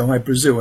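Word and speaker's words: Hy-Brasil: There's High Brazil, people pronounce it High Brazil, and it's Brazil, okay. – yeah. But it's High Brazil because that's how the Irish would pronounce it, because it's an it's an island that's off the southwest coast of Ireland.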